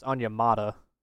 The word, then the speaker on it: Onyemata: So first, I looked up the pronunciation. It's Onyemata.